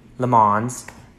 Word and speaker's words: Le Mans: Takes place at the 66 Le Moines 24 hours. Of course, I know I'm not even saying that right, but Le Mans. There you go. I said it right that time.